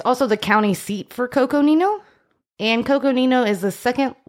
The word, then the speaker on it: Coconino: You butchered that, I'm sure, but... – C-O-C-O-N-I-N-O. Coconino. – Yeah. – I didn't butcher it.